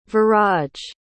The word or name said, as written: viraj